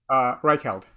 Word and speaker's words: Reichheld: Let me ask you some tactical questions as, you know, because you have a relationship with Fred, who created, he's at Bain, Reinhold, am I saying that right? – Reichheld. – Reichheld.